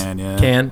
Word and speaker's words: Cannes: You have like Sundance, you have Cannes, you have, or Cannes. I don't even know how you pronounce that. TIFF and all these ones. – Can.